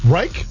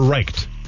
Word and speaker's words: Reich: R-E-I-C-H. – Yes, Reich. – Reich or Reicht? – It's Reich with no T. – Reich, okay. So it's R. It's like R-I-K-E.